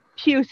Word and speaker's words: Pius: It looks like Pius Suter because it's P-I-U-S, but it's Pius Suter. – Okay, I can live with that. – And I think it's Pius is better than Pius.